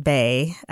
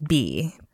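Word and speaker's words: Bey: Steve, you actually, the pronunciation is not Bay, it is Bee.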